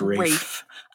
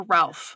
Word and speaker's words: Ralph: But I only learned a couple of years ago that his name is pronounced Rafe. – I wasn't sure if it was Rafe or Ralph.